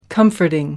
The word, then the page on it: comforting: American English